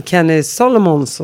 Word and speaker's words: Kenny Solomons: Idag är ingen mindre än skådespelaren och entreprenören Kenny Solomons hos mig.